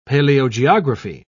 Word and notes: paleogeography: British English

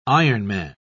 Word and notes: ironman: British English

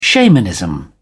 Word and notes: shamanism: British English

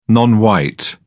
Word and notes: non white: British English

